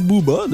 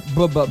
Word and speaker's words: Bubba: On devrait dire Booba, non? – Vous écrivez comment? – Baba. B-U-B-B-A.